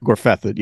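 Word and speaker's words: Gorfyddyd: I think it's Gorfefid is like how you say it. – Gorfethid, yeah. Gorfethid. – Well, at least yeah. – How they did in the books. I think that's how they say it on the show too.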